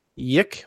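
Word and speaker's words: YIIK: I think it's pronounced Yik. – Yik.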